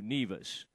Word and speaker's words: Nevis: How do you say that? Is it Nevis or Nevis? – Nevis. – Uh-huh, Nevis. – Nevis. Okay.